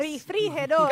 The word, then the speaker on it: refrigerador: Refrigerador. – Carro. Your turn. – Yeah, everything about that is because you have to roll your R to get the right pronunciation right. – for that word.